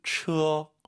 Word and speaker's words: 车: 굳이 한국어로 대입을 시키면 츠로 시작하는 것 같아요. 츠, 츠어. 네. 처, 처, 처 하지 마시고 츠어 두 글자로 생각하시고 발음하시기 바랍니다.